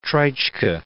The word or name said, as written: Treitschke